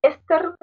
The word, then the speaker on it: Esther: O por ejemplo, Esther, no logro que diga Esther, dice Esther le pone el acento en la otra letra. Esther, yo digo Esther, no, Esther.